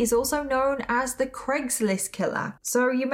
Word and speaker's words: Craigslist: And listen, guys, I know it's pronounced Craigslist, but I'm British and we pronounce Craig as Craig. So please excuse me if sometimes I say Craigslist instead of Craigslist.